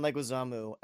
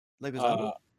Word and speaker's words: Leguizamo: And then, how do you pronounce his last name? – John Leguizamo as Luigi. – Thank you. I think that guy's hilarious. – Leguizamo.